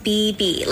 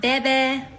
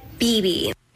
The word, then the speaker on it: Bebe: My name is BB, like two B's, not Bebe, BB.